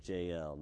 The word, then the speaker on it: Jael: And that is how it's pronounced, by the way, Jael, not Jael, Jael, the wife of Heber, the Kenite.